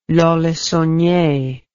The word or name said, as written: Lons-le-Saunier